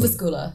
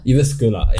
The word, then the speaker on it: Jyväskylä: I think it's called Ivlaska, if I'm not wrong. Jyväskylä. – Jyväskylä. Okay, definitely butchered that there.